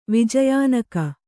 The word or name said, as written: ವಿಜಯಾನಕ